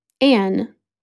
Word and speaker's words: Anne: Anne, spelled A-N-N-E.